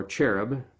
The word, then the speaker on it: cherub: Enter the dragon, the serpent of old, the devil and Satan, who is a great angelic cherub, or cherub, for if you like to say the CH, as it's pronounced with a hard C in England, where I learned it, who rebelled against God and prepared to destroy God's plan.